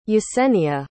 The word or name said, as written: yesenia